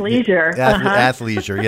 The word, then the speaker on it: athleisure: From that standpoint, athleisure. – Yeah, that's it. Yeah, the ick is out of there. Just take the ick right out of that and then make things, athleisure.